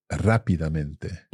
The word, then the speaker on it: rápidamente: Rápidamente. – Now, I have to be honest. I think until this moment in my entire Spanish speaking life, I've mispronounced that word and said rápidamente.